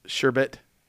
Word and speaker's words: sherbet: It is pronounced sherbet. – Sherbet? – Bit.